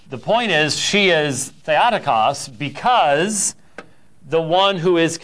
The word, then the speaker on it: Theotokos: Which I think I've talked to you about before, which I believe in modern Greek would be Theotokos, yes? Being the Erasmian pronunciation guy that I am, I would pronounce it Theotokos, but anyways.